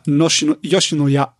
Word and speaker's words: Yoshinoya: Denn die japanische Beef Bowl-Kette wie spricht man die aus? Yoshinoya? – Ja, Yoshinoya.